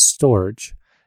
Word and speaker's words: Storj: Uh, in terms of uh, uh storage, is is how it's pronounced, uh, and if you're uh, a lot of people in Europe like to say J, um, and so the official answer is it's storage.